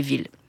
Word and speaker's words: ville: Et c'est là qu'à ville, on a décidé de remettre 2L pour le rapprocher de la villa, mais on a continué de le prononcer comme on le prononçait déjà, c'est-à-dire on a continué de le prononcer ville.